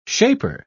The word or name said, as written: shaper